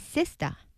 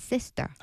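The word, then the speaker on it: Sistar: When referring to Matt Clown's first mainstream hit, Stupid in Love with Soyou, the mispronunciation of her group name is Sista, not Sister.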